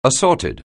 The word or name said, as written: assorted